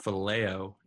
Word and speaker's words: phileo: Correction. Actually, the verb form is pronounced phileo, not filio. So every time I say that in this podcast, please correct it to phileo.